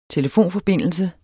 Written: TELEFONFORBINDELSE

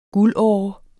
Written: guldåre

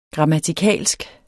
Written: grammatikalsk

